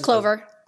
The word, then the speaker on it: Clovyr: I believe it's Clovier is the pronunciation. – Just Clover.